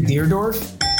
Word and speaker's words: Dierdorf: Is it Dan Dierdorf? – Yep, and you said it correctly.